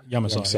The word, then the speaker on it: Yamasa: Like this month, it was what the Davidoff Yamaka. I'm probably saying that wrong. – Yamasa. – Yeah.